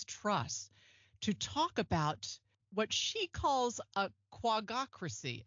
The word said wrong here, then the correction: quangocracy: Am I saying that right? Quangocracy.